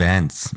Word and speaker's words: Vance: Vance. – Vance, ja, ich bitte um Verzeihung, ich habe das eher jetzt die britische Aussprache, genau, aber es ist natürlich Vance.